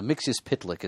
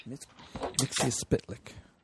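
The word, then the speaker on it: Mxyzptlk: Uh, well, there have been a couple, but uh, Mixius Pitlick is the one I favor. Mixius, ah, fantastic. – Mixius Pitlick.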